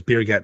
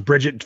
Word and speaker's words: Birgit: Literally, her name was Birgit. I'm like, you couldn't be, I think it's pronounced Bridget for us. – Yeah. – But it's Birgit for them.